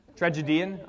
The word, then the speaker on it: tragedian: Where does the accent fall in that word? Tragedian. Okay, we'll do that. And then you could say if it was a woman, it'd be a tragedienne, right?